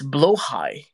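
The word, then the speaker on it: Blåhaj: The blah the blah the blah the It's blow high. No, that's stupid. It's blahaj is better. The blahaj is better. I would rather call him blahaj because blahaj sounds fancier. Blahaj, blahaj, blahaj. Yeah, tell Swedish people to change their pronunciation on that.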